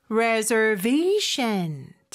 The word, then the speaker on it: reservation: Reservation. 자, 강세 들리셨죠? 그리고 S 발음은 Z 발음으로 reservation. 이렇게 발음이 되죠.